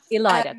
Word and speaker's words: Ilayda: Can I just ask really quickly, is it Ilayda? – It's Ilayda. – Ilayda, great. – If you forget it, it rhymes with spider.